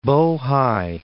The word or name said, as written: Po Hai